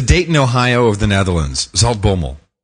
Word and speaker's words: Zaltbommel: Zaltbommel.